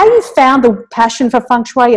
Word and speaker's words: feng shui: I hope I'm saying that correctly. Feng shui? – Yeah, feng shui is fine.